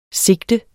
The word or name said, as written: SIGTETS